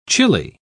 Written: chile

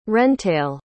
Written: wrentail

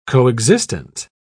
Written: coexistent

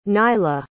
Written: nayla

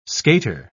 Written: skater

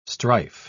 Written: strife

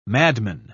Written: madman

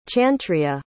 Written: chantria

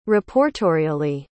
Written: reportorially

